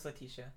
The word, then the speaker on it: Letitia: Is it Letitia? – It's Letitia, yeah.